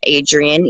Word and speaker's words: Adrienne: It is Hobo Chic by Adrienne. – Can you spell that? Because people don't know how to spell it. Hobo, H-O-B-O, and then chic with a C, so C-H-I-C, and then by B-Y, Adrienne, A-D-R-I-E-N-N-E.